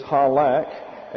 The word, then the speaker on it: halakh: It is halak, H-A-L-A-K-H, halak, if you're Jewish, and that'll be the last you'll hear of me pronouncing it in an effort to do so properly.